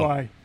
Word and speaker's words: qi: And it's pronounced qi, correct? – Yeah. Right.